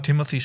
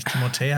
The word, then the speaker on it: Timothée: Ja, aber ich sage auch immer Timothy Chalamet, obwohl der eigentlich Timothée heißt, aber sich selber ich sag auch Timothy immer so Chalamet.